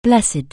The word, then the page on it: blessed: anglais